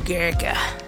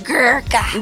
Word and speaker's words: Gurkha: Now you gotta say it like Gurkha. – Gurkha.